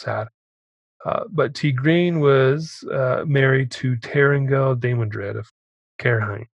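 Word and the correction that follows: Cairhien: Oh, here it is. Pronounced Ki-ri-en. – Wow, we were way off.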